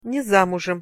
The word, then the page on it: ne zamuzem: Russian